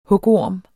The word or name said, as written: Hugorme